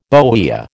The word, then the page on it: bohea: American English